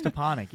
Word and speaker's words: Stepanek: No, it's Stepanek, yeah. A lot of people mispronounce it, and I always go with it.